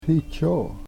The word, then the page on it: hichoo: Yurok